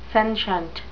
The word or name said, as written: sentient